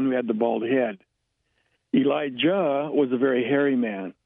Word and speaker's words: Elijah: Which was the and this was the event that occurred right after Elijah, or Eliah, is actually a more correct pronunciation of the name, had ascended in a fiery chariot to the eternal heaven.